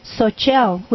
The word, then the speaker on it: Xochitl: It's spelled X-O-C-H-I-T-L. But you see, we don't know how to deal with the X in our culture. – No. – So we make it an S.